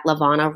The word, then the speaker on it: LaVonna: Or you can follow me directly at LaVonna Roth.